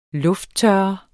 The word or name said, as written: lufttørre